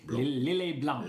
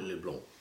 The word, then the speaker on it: Lillet Blanc: Sorry, you don't pronounce the C. Lillet Blanc. – Lillet Blanc.